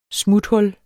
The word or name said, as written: smuthul